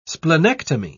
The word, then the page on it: splenectomy: British English